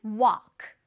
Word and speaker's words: walk: 자, 그 다음은 walk. Walk. 걷다라는 뜻입니다. 이것도 walk라고 읽히지 않아요. L 소리가 나지 않습니다.